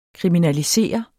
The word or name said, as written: kriminalisere